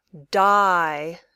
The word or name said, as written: die